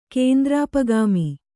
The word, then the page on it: ಕೇಂದ್ರಾಪಗಾಮಿ: kēndrāpagāmi